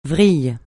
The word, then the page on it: vrille: francais